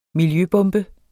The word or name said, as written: miljøbombe